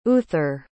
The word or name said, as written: uther